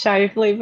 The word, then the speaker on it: Shively: Have I said that right? – It's Shively, but Shively.